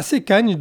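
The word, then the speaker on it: Sečanj: À Sekanj, donc ce village qu'on prononcera Sekanj, la police anti-émeute a repoussé dans la soirée des citoyens qui s'étaient rassemblés pacifiquement près du bâtiment municipal.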